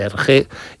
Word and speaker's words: Hergé: y utilizó la R de Remy, que allí se pronuncia R, R de Hergé, y el nombre de George G. RG.